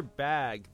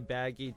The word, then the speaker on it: Bagge: Yes, true believers, that is how you pronounce it, even though it's spelled B-A-G-G-E. – I was just going to say, because I've heard people say Baggy too, but I like Peter Bagge because it's P.